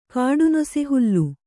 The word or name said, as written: ಕಾಡುನೊಸೆಹುಲ್ಲು